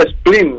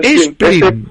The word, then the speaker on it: Spleen: Sí, es Sleeping, sí. ¿No? – No, Spleen. – Spleen. – S-P-L-E-E-N.